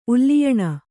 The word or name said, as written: ಉಲ್ಲಿಯಣ